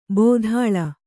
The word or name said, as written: ಭೂತಾಳ